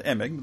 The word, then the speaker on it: Emig: Am I pronouncing that correctly? – Uh, Emig, the softie.